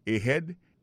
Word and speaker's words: ahead: Ahead. Ahead, là, c'est A-H-E-A-D. Ahead. Ahead. Go ahead. Là encore, on a vu que l'accent tonique là tombé sous deuxième syllabe. Là, son mot gagne deux syllabes.